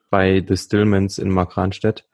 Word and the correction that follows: Markranstädt: Ähm, ah, Markranstädt heißt es ja ausgesprochen.